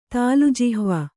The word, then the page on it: ತಾಲುಜಿಹ್ವ: tālu jihva